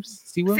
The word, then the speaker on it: Sewell: I think how you say his name? Sewell. – Sewell. Yeah, Sewell.